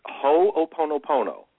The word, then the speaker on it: Ho'oponopono: You're darn close. It's Ho'oponopono. – Ho'oponopono. Shame on me. – You're just pronouncing every syllable, and it's a Hawaiian name.